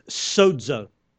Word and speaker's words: sozo: The word is sozo. Sozo. You've heard me talk about it before. Sozo. Some people spell it S-O-Z-O, I think, in the Greek.